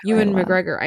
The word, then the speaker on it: Ewan McGregor: Ewan McGregor? – Ewan McGregor.